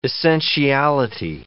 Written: essentiality

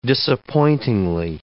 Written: disappointingly

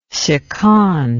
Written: seconde

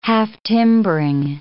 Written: half-timbering

